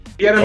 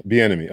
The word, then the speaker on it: Bienemy: How do I see your name, brother? – Bienemy. – Bienemy, okay. like the offensive coordinator for the Chiefs, Eric Bienemy, pronounced the same way. – Okay, Bienemy.